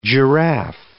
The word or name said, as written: giraffe